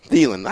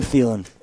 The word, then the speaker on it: Thielen: Thielen. – I don't know how to say his name. – Saying like Maybelline.